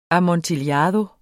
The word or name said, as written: amontillado